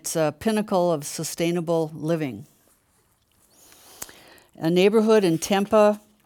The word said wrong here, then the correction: Tempe: is that how you say it? I don't know. Arizona, huh? – Tempe. – Thank you for the correction.